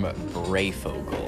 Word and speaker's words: Breyfogle: And Norm Breyfogle.